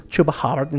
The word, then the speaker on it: Chuba Hubbard: se termina lesionando, abandona temprano, relativamente temprano el juego Christian McCaffrey tuvo que pues entrarle al kit de Chuba Hubbard, porque ya escuché que lo pronuncian como Chuba. Yo pensé que se pronunciaba Chuba Hubbard. Le dicen Chuba Hubbard, ok. Le decimos Chuba Hubbard, entonces.